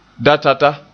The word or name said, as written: datata